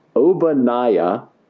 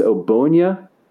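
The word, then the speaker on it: Ogbonnia: The way I thought it was is Obanaya. – Yeah. – Okay, but it's not. It's Obonia.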